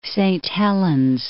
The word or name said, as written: Saint Helens